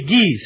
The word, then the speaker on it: ghee: Ah, sí, ghee se pronuncia.